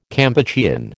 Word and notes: Kampuchean: American English

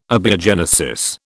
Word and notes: abiogenesis: British English

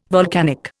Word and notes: volcanic: Indian

